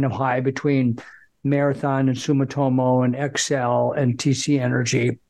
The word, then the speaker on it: Sumitomo: and then Sumitomo.